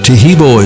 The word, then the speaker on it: Taheebo: Taheebo is spelled T-A-H-E-E-B, like boy, O, then the word tea, and then the word club.